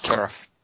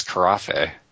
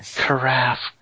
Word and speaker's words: carafe: Kerif. It's a Kerif. How would you actually know to pronounce that way? That makes no sense. – Because I think it is the accent over the A. And one over the E. It's Carafe.